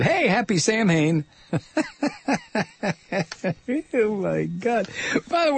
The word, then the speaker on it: Samhain: This is our Samhain program. Samhain. It's written as though it were pronounced Sam Hain.